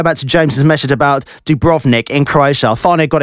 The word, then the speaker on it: Dubrovnik: So it's Dubrovnik. There you go. Dubrovnik.